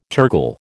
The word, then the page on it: tergal: American English